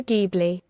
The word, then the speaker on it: Ghibli: Studio Ghibli. – No, it's still a hard G noise.